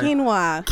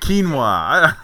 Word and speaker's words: quinoa: Quinoa? It's Q-U-I? – Quinoa. – Quinoa.